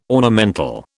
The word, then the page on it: ornamental: British English